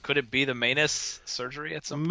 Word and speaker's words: Maness: Maness is how it's pronounced. – I did have to look that up because I've heard it both like multiple ways, and Maness versus Maness. Yep. So it's pronounced Maness like a.